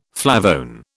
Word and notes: flavone: British English

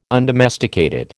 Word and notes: undomesticated: American English

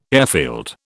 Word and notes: airfield: British English

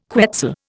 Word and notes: quetzal: Indian

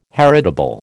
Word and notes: heritable: American English

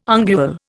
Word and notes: ungual: Indian

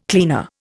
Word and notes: cleaner: Indian

